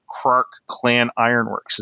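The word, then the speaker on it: Krark-Clan Ironworks: And they just don't know how that interaction works with Krark Clan Ironworks. Is that how you say it? KCI. – Yeah. – Yeah. That, yep. That card.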